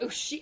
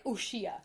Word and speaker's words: Uxia: wrote Ushia I wrote Ushia, O O S H I E, and then found out later it was spelled U X I A, and yeah.